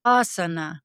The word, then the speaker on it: asana: Asana. – So people will often pronounce this word, as you just said, and I always tell them it's not a hot room. It is asana.